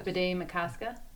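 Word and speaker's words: Bdé Maka Ska: Bidet Makoska. It's a little bit hard to... It's spelled BDE and then MK... – MAKA. – MAKA. – So. It's not hard S-K-A.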